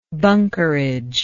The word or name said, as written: bunkerage